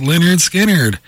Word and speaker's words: Lynyrd Skynyrd: Lynyrd Skynyrd, Tuesday's Gone, pronounced Lynyrd Skynyrd.